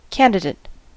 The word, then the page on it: candidate: [ˈkæn.dɪ.ˌdeɪt]; /ˈkæn.dɪdət/; /ˈkæn.dɪ.deɪt/